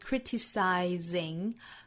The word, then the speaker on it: criticizing: Whenever you feel like criticizing, 这个词重读哈，应该注意在第一个音节。